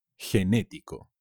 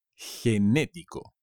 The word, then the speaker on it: genético: Genético. Con un acento en la segunda E. Genético.